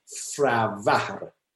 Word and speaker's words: فروهر: اصل این کلمه رو اگه درست تلفظ کنید هست فروهر